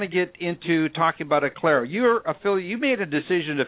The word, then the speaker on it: Aklero: but that's spelled A-K-L-E-R-O, Acclaro.